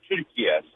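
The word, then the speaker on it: Türkiye: The way Turkish people pronounce it is actually the way it's said, Turkiye.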